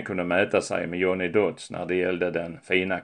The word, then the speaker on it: Dodds: Hans namn stavas Dodds med två D men uttalas med T, Dodds.